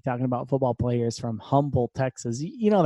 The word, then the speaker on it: Humble: Like, right outside of Houston, there's a town called Humble. It's spelled with an H. It's spelled with an H, but we all call it Humble. Instead of Humble, we call it Humble.